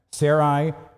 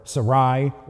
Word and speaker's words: Sarai: And by the way, it can either be pronounced Sarai or Sarai or Sari, and you might hear all three of them from me.